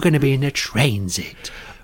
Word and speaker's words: Transit: Classic Transit. Although I realise I've just said it wrong. Of course, it is Transit.